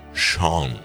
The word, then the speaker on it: Sean: no es Sin, por favor, es John, la versión escocesa de Juan. Sean, se pronuncia Sean, como Sean Connery.